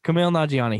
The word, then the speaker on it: Nanjiani: Kumail, uh, I don't know how to say his last name, but Kumail he, Nanjiani. Kumail Nanjiani, he's in that movie.